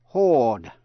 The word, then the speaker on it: hoard: Hai chữ sau đây phát âm giống nhau. Hoard và hoard. Hoard, H-O-A-R-D, là lưu trữ, là chữ, tích trữ. Và hoard, H-O-R-D-E, là đám đông người. Chữ hoard, H-O-A-R-D, vừa là danh từ, vừa là động từ.